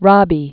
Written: Rabi

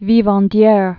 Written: vivandieres

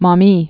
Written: Maumee River

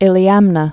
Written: Iliamna Lake

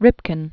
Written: Ripken, Calvin Edward, Jr.

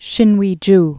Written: Sinuiju

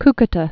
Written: Cucuta

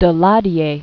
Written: Daladier

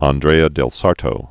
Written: Andrea del Sarto

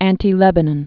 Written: Anti-Lebanon Range

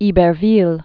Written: Iberville

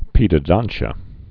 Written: pedodontia

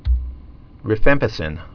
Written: rifampicin

